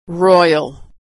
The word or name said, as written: royal